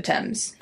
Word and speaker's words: Thames: Also, just in case it happens, I always want to read it as the Thames. – Okay. – And I know some people call it that, but apparently in England it's the Thames. – Uh-huh. – So I'm trying to say it correctly.